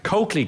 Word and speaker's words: Colclough: Coakley Gardens is how you pronounce it. C-O-L-C-L-O-U-G-H.